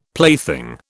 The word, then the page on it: plaything: British English